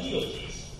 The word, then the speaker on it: litotes: Legal tease. It's spelled L-I-T-O-T-E-S. It's a legal tease.